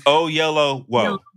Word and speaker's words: Oyelowo: You talking about David Oyelowo? – Yeah. – David. You break it down. It's Oyelowo.